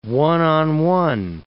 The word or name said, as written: one-on-one